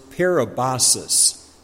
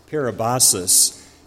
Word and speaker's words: parabasis: The first word we come to today is pronounced parabasis, parabasis. And the typical translations here would either be transgression or violation.